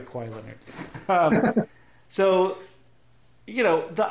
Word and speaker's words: Kawhi: Kawhi.